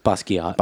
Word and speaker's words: Basquiat: Okay, I had Basquiat. Is that how you say that? Or is it Basquiat? – It's Basquiat.